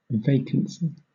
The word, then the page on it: vacancy: British English